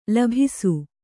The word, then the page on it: ಲೋಭಿಸು: Kannada